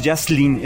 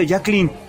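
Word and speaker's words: Jaclyn: Jacqueline, no es Jaslyn, es Jacqueline. No, se pronuncia Jacqueline. Busca los videos, sí. Es Jacqueline. No, Jocelyn.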